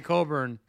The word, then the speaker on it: Cockburn: My last name's Cockburn, but call me Coburn. But it's Cockburn, so that's fine.